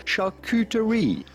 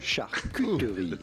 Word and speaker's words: charcuterie: You do want to insist on the Q part, and it's Q, not coup, because yes, it is a French word and a name of French origin, and the French pronounce the U, charcuterie.